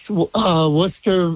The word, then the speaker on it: Worcester: And, you know, you pronounce Worcester very well.